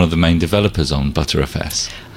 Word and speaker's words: Btrfs: ButterFS.